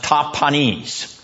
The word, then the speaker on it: Tahpanhes: It's pronounced Tahpanhes.